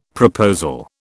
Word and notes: proposal: British English

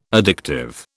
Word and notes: addictive: British English

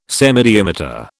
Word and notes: semidiameter: British English

